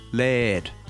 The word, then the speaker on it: Laird: Just say air and then start with an L and finish with a D. Laird.